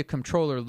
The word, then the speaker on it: comptroller: Yes, and you're actually pronouncing it right. – Ah, yes. I try to, like, combine the M and the N, comptroller, you know, and just skate by.